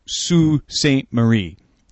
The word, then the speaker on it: Sault Ste. Marie: Marie is pronounced Sault Ste. Marie.